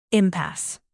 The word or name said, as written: impasse